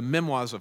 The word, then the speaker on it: memoirs: In fact, the early apologist, Justin Martyr, even referred to Mark, the Gospel of Mark, he called it memoirs, how do you say that? Memoirs, does that work? Memoirs? Okay, memoirs. The memoirs of Peter.